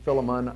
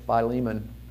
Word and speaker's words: Philemon: And in that short little letter, and people pronounce this differently. Some pronounce it Philemon. I pronounce it Philemon.